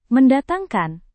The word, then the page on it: mendatangkan: Indonesian